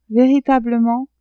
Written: veritablement

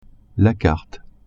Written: carte

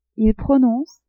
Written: ils prononcent

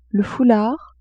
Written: foulard

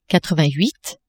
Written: quatrevingthuit

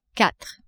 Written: quatre

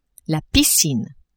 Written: piscine

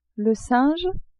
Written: singe

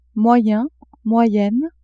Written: moyen-moyenne

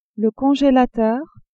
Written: congélateur

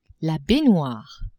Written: baignoire

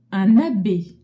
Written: abbe

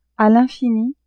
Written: a l'infini

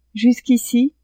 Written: jusqu'ici

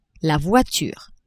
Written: voiture

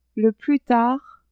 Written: le plus tard

